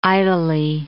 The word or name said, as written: idly